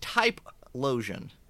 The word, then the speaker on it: Typhlosion: I believe that's pronounced Typhlosion. – I can't stand you. – Typhlosion.